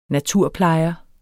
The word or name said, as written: naturplejer